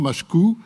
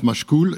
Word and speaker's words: Machecoul: Alors, on dit souvent Machecou, mais comme il y a un L final, certaines personnes prononcent Machecoul et ça ne pose pas de difficulté.